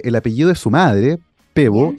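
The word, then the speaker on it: Pääbo: Eh, me refiero, por supuesto, al ganador del premio Nobel de Medicina que fue anunciado el día de ayer, el sueco Svante Pebo. Se escribe P-A-A-B-O, las dos A con cremillas arriba. Sí. Pebo, eh, que nació el 55.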